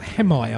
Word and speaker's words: Hemai: And then we had music from Hemai. Actually, it's pronounced Hemai. I've just recently found out. I've been pronouncing it wrong, even though we signed him on True Thoughts. Spelled H-E-M-A-I.